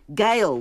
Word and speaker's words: gaol: wanted Gail with a heart surrounding it, and the tattoo is spelt it G-A-O-L.